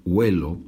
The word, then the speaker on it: huelo: Hacemos como si tuviera una G, porque es difícil decir huelo. Entonces decimos huelo, huelo. Pero no se escribe con G, se escribe con H.